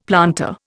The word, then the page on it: planter: Indian